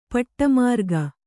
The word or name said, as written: ಪಟ್ಟಮಾರ್ಗ